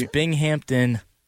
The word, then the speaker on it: Binghamton: It's Binghamton. – It's Binghamton? – Yes.